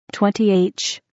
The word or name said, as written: 20th